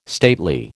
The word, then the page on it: stately: American English